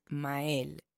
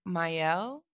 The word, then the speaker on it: Mael: My name is, the correct pronunciation is Mael. – Mael. – Yes. – And people have trouble saying that. – Yeah. In English, they'll say like Mael. They just absolutely want to get that Y in there, or Mael.